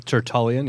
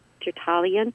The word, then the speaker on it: Tertullian: Tertullian. Did I say it right? – Tertullian, yep. Yep. – Yep, Tertullian.